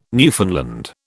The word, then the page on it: Newfoundland: British English